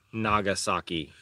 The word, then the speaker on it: Nagasaki: Nagasaki.